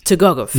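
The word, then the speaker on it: Tukhugov: How do you say his name? Tugogov.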